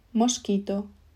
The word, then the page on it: mosquito: Spain Spanish